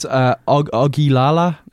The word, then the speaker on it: Ogilala: How do we pronounce the album? – Oh, God. Best of luck to you. – Yeah, sorry. It's Ogilala. – Sure. – Ogilala. – Why not? – Ogilala.